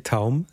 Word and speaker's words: Taum: So the title is, as you pronounced it correctly, Taum. It's T-A-U-M.